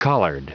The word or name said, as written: collared